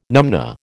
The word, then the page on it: numnah: American English